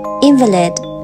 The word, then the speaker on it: invalid: Hey, 今天我们要学的词是 invalid, invalid. 大家要特别注意这个词的读音，它的重音既可以在前面，也可以在后面，还可以发成 invalid。